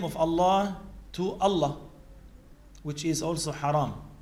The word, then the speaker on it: Allah: Even if they emitted the alif, because when we write the term Allah, it is alif, lam, lam, and ha. But we pronounce it as Allah. So there is an alif, which is not written.